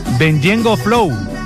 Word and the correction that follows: Ñengo Flow: Con el mismo Ñengo Flow.